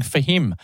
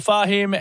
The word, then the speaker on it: Fahim: I've been saying Fahim. I've been putting the wrong emphasis on the wrong syllable. Rachel Fahim, our artist of the week this week.